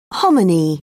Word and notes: hominy: British English

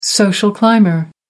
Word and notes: social climber: American English

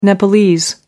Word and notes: nepalese: American English

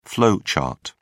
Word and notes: flow chart: British English

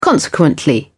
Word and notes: consequently: British English

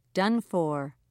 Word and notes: done for: British English

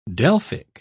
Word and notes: delphic: British English

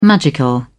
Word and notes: magical: British English

